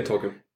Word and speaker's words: Tolkien: Yeah, Tolkien.